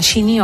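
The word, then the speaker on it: Sinéad: Me encanta decir además que se dice, perdonadme la pedantería, Sidney O'Connor. – Sidney O'Connor. – Porque me lo enseñaron en Irlanda.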